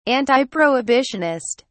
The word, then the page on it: antiprohibitionist: British English